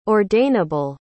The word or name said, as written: ordainable